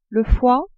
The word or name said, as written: foie